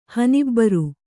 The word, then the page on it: ಹನಿಬ್ಬರು: Kannada